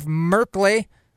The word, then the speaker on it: Merkley: So the measure, sponsored by Senator Jeff Merkley, which rhymes with Sperkley, passed in the Senate Appropriations Committee on a voice vote.